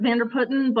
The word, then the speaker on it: Vanderputten: I pronounce it Vanderputten, but my Dutch father-in-law tells me that I don't even get it right, so don't worry too much about it.